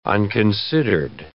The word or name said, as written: unconsidered